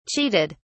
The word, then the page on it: cheated: British English